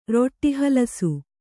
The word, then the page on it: ರೊಟ್ಟೀಹಲಸು: roṭṭī halasu